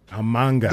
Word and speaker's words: Mhango: Please make sure that next time when you pronounce it, Gabadinho Mhango. – Mhango.